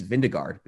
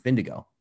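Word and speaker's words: Vingegaard: It reads Vindegaard, but you pronounce it Vindigo.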